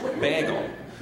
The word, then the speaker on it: bagel: Like growing up, I, guess I understand now that you're supposed to pronounce it like there's a Y in there that's not in there, not like it's the word bag, like grocery bag, or Bilbo Baggins, bagel.